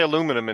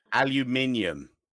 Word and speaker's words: aluminium: How do you say aluminum in English? – Aluminium. Like the rest of the goddamn planet says aluminium.